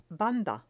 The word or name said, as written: banda